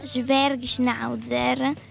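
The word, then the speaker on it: Zwergschnauzer: E l'abbiamo preso in un allevamento ed è una razza tedesca, quindi si chiama Schwergschnauzer. – Senti che pronuncia, io non saprei ripronunciarlo.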